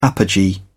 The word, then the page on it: apogee: British English